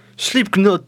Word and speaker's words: Slipknot: Slipknot. – Voilà, le cas ne se prononce pas, voyons.